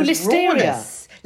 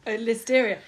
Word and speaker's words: Listeria: Maybe he's given himself just sort of constant salmonella / dysentery from all the Lytheria. What's it called? That thing you can't do when you're pregnant. All the terrible bacteria that's in all the Listeria. – Listeria. There we go. – Listeria. – Rhymes with hysteria.